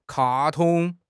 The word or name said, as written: 卡通